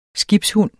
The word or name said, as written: skibshund